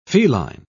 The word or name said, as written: feline